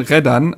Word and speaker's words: Redan: Können wir uns glaub, bitte auf Redon einigen? Ich finde es gut. Ja, ich glaube, der heißt wirklich äh, Daishon Reddan, also quasi Betonung auf das, äh, auf die zweite Silbe seines Nachnamens.